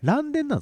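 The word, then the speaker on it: 嵐電: あれアラデン、アラデンって読むんですか、ランデンって読むんじゃないですか。 – あランデンなんですか。僕アラデン、アラデン言ってましたけど。 – あら嵐でしょ、嵐の電車でしょ。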